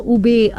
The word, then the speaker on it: Ube: Ube, U-B-E, इसे spell किया जाता है U-B-E, और pronounce किया जाता है as ube, जो एक naturally purple yam है.